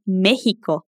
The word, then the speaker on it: México: É por isso que se escreve México e mexicano com X, mas o som é o mesmo do J do espanhol.